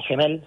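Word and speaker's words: Gemmell: Se la cambió a un jugador escocés, se llama Tommy Gemel, eh, Tommy Gemmel, sí, está bien pronunciado, un escocés.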